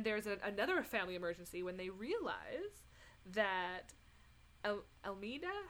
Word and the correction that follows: Imelda: how do you say her name? Emel- Mama Imelda.